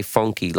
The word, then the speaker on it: Fonky: I say funky like that because it's spelled F O N K Y, which is telling me the way I need to say it funky, which is the way he says it.